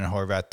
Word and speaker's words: Horvath: We've got Sean Johnson, who you presume is, you know, this third or second Ethan Horvath, or how do you pronounce Horvath. it? Horvath. Horvath.